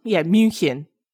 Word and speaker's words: München: München.